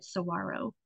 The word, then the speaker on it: saguaro: We pronounce it saguaro.